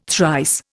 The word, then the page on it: thrice: Indian